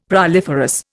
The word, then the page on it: proliferous: Indian